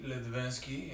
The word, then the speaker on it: Litvinski: My name is Yuri Ledvinsky, and it is spelled Y-U-R-I-Y. Starts with the Y, ends with the Y. Last name Ledvinsky is spelled L-I-T-V-I-N-S-K-I.